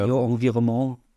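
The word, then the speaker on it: Guyot Environnement: Wir hatten jetzt von eurem ähm, Ocean Race Team, vom Team Guyot Environment Team Europe, habe ich es richtig gesagt? Also, ja. auf Fall nicht richtig genau. ausgesprochen, genau. aber. Äh, Guillot Environnement. Genau, der, der, der Sponsor ah. titelt, titelt sich Französisch und dann Team Europe im Englischen.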